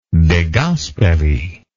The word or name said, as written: De Gasperi